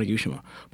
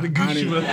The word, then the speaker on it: Onigashima: Onigashima. But I kind of want it to be, like you mentioned, a struggle. I said it wrong again. – Onigashima. – Onigashima.